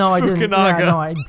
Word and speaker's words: Fukunaga: Seriously, And he seems to work really well with Fukunaga. Did I say that right? – Fukunaga? No, it's just Fukunaga.